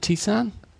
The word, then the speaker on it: tisane: I think that's how you pronounce it, isn't it, tisane? – Yeah. – Tisane. – Tisane sounds better because it sounds like tea.